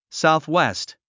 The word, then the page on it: southwest: sàuθwést; サウスウェスト